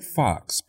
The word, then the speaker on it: Fuchs: Hobbs sent a letter to University of Florida President Kent Fox. People often confuse the pronunciation of Fox's name because it is spelled F-U-C-H-S.